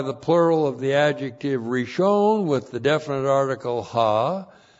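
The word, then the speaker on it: Rishon (ראשון): R-I-S-H, long O-N, Rishon.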